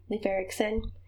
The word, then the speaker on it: Leif Erikson: His name was Leif Erikson. I'm not sure if that I'm saying that right. – Yeah. – Leif Erikson.